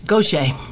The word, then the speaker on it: Gaucher: It's a Louisiana pronunciation of a French name. It's impossible stage name. I should have changed it in the beginning. But in Louisiana, it's a common name. I didn't realize I'd be up against the level of difficulty. It's Gaucher, yeah.